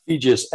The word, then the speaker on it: Feges: I've heard Feges rhymes with Regis. My last name, it's Hungarian, and it is fun to say.